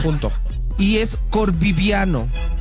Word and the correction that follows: Corviniano: No. – Corviviano, Corviniano. – Sí.